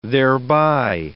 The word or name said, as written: thereby